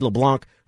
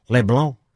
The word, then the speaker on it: LeBlanc: That's the worst thing that happened to the Mariners, is Wade LeBlanc, who actually found out, you know how his name's pronounced? LeBlanc.